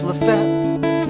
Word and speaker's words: Lafayette: Lafayette is pronounced Lafette.